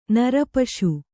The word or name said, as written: ನರಪಶು